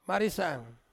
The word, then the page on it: marisang: Ansus